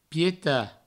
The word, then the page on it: pietat: pieta